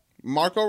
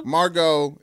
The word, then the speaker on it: Margot: Margot. It's a T silent T. Margot Robbie.